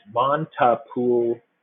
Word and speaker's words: Montepulciano: It's Montepulciano or Chano, C-H-A-N-N-O.